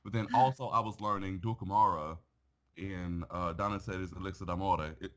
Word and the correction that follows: Elisir d'Amore: Elisir d'Amore, let me say it correctly.